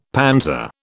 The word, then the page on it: panzer: British English